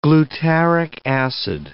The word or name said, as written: glutaric acid